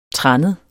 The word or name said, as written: trannet